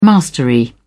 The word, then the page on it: mastery: British English